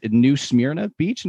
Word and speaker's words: New Smyrna Beach: But I will say that I was actually at um I'm gonna butcher the name probably the city is New Smyrna Beach in Florida.